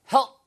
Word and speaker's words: help: 이거 help 이렇게 붙이시면 안 돼요. 발음이 help 하고 누가 옆구리를 살짝 help 이렇게 하는 느낌으로 하셔야 P 발음이 다음에 우 소리가 들어가지 않습니다.